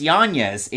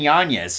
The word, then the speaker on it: Yanez: So he's tied there with Adrian, it's pronounced Yanez, Adrian Yanez.